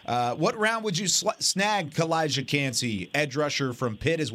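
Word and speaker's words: Calijah Kancey: Kalijah Kancey. I said his name wrong. Butchered it in the first segment. That's okay. Got it right this time.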